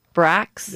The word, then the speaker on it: bracts: Bracts.